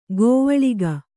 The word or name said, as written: ಗವಳಿಗ